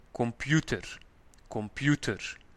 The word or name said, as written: de computer